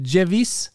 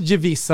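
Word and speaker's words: Gewiss: Eh, och ska man uttala det på italienska med GE, då blir det mjukt G. Då blir det Gevis.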